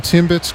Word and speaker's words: Timbits: Did I say that right? – You Timbits? – Did. – Timbits? Yeah. – You got Timbits correct.